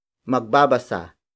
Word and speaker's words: magbabasa: Magbabasa, ako, ng, aklat. Magbabasa, ako, ng, aklat. The first word is magbabasa. Magbabasa. Magbabasa. Magbabasa is spelled as M-A-G-B-A-B-A-S-A.